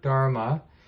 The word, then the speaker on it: dharma: So the word dharma, you hear a lot. Sometimes you hear it as dhamma. That's the Pali version. And then it's pronounced dharma in Sanskrit.